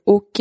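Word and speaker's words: Åke: C'est une A avec un rond euh, au-dessus. Ça se prononce O. Ensuite, c'est K-E, et ça fait OK.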